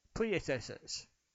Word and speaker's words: Priuses: How do you say it's two? Priuses.